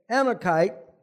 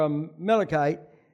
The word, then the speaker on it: Amalekite: By the way, Amalekite, or Amalekite, either pronunciation is good, means dweller in the valley.